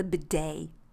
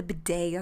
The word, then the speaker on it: bidet: I had the bidet, and when you look at it, I think it's French as well. The bidet, I spell it that way, like D-A-Y, but it's B-I-D-E-T.